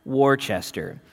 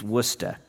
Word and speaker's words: Worcester: There's a town that looks like it should be pronounced Worcester. It's Worcester.